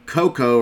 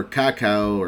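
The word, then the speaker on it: Kakao: Cocoa or cacao, or it's kind of like spelled like cocoa, like the chocolate bean, like cocoa beans, but it's with a K. K-A-K-A-O.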